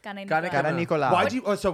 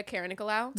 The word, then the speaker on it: Karanikolaou: Greek. Anastasia Karanikolaou. That's how you say it. What do you want me to say? Anastasia Karanikolaou? – No.